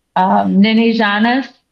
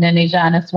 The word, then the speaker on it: Niniijaanis: But hey, we have the CEO of Niniijaanis, and I hope I said that right, because I've been practicing. Welcome to Native Roots Radio. – Bonjour. Niniijaanis is correct, and that means loosely in Ojibwe, my child, and Niniijaanis One of Ones is a social enterprise for Indigenous children.